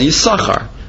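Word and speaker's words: Yisachar (יִשָּׂשכָר): We don't say Yisachar. We call it Yisachar as if there's only one. One of the Sins are silent.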